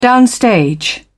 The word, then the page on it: downstage: British English